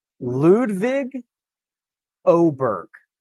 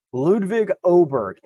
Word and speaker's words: Ludvig Åberg: One, I'm sitting next to a very nice gentleman from Swedish television, and he told me that the acceptable English pronunciation would be Ludvig Åberg. – I can that. – I can do that as well. So I'll have to get that into my brain. Ludvig Åberg is acceptable.